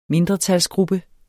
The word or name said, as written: mindretalsgruppe